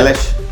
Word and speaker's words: Eilish: Eilish.